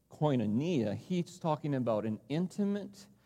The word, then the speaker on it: koinonia: And we saw that there's a priority that we need to make for koinonia. And our friend Dustin told me, I think the correct pronunciation is gonna need you.